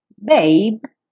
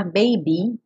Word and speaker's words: babe: Babe, sem pronunciar o E, e cuidado para não falar baby, porque essa é outra palavra, uma variação.